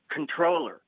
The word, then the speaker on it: comptroller: It's spelled C-O-M-P-T-R-O-L-L-E-R and pronounced controller. – Oh, it's not comptroller? – No, it's controller. It's one of those silent P's.